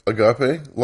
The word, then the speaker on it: Agape: Good old, I like the Agape Lodge. Is that how it's pronounced? – Yeah. – Yeah, because to me it just looks like a gape.